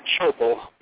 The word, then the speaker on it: Schoeppel: Schoeppel.